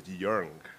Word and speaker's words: Young: So according to my grandmother, the Chinese version of my name is Yung.